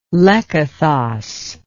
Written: lekythos

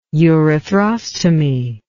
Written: urethrostomy

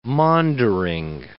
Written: maundering

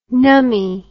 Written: numbie

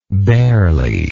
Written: barely

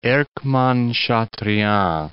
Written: Erckmann-Chatrian

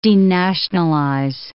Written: denationalize

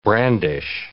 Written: brandish

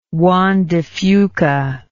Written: Juan de Fuca

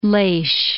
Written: Laoighis

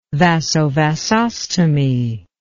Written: vasovasostomy

